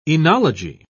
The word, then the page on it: oenology: British English